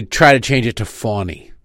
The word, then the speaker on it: Fani: No, Fawny has a W in it. Fanny is spelled F-A-N-I. Her name is F-A-N-I, right? This isn't a Kiev situation where we just decided to pronounce it different. There's no W there. There's no world in which F-A-N-I has a silent W in it. Well, it's pronounced with a W.